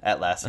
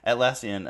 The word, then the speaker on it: Atlassian: Atlassian. I'm just gonna say Bitbucket from now on. – Yeah, Atlassian.